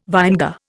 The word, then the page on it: vinegar: Indian